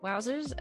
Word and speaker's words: Vvowzerz: I am Wowzers. That's spelled V-V-O-W-Z-E-R-Z.